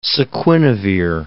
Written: saquinavir

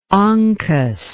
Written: onkus

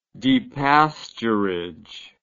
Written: depasturage

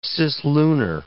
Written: cislunar

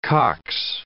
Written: cox

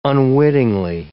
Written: unwittingly

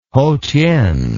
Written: Hotien

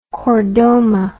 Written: chordoma

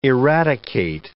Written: eradicate

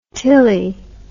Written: Tilley

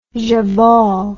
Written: jawohl

